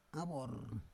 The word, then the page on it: avor: Roon